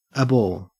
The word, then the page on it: abhor: Australian English